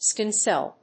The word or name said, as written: skin cell